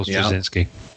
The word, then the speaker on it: Straczynski: So the guy who wrote Babylon 5 and Silver Surfer Requiem, who I can't pronounce his name, and Jay Michael Straczynski.